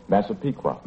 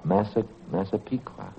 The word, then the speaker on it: Massapequa: How do you say that? – Massapequa. – Massapequa. Huh? – Old Indian.